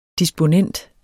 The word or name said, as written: disponent